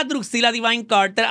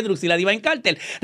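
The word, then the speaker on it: Dreuxilla Divine Carter: Bienvenidos a un episodio más de Muriendo de Bella con esta, la más perra de todas, la Druxilla Divine Carter.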